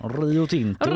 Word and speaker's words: Rio Tinto: Den eh, användes i strålningsutrustning av gruvjätten Rio Tinto. – Aha. – Oj vad svenskt jag uttalade det. – Rio Tinto. – Rio Tinto.